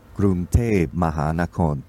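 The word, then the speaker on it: Krung Thep Mahanakhon (กรุงเทพมหานคร): Krung Thep Mahanakhon Amon Ratanakosin Mahintara Yutaya Mahadilokpo Nopara Ratchatani Burirom Udom Ratchaniwe Maha Satan Amon Piman Awatan Satit Sakatatia Witsanukam Presit.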